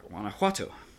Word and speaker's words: Guanajuato: Guanajuato. – Yeah, Guanajuato.